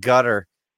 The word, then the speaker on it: Qatar: I think one of the senators was saying it gutter on the No Agenda show. – It is pronounced as gutter, yeah.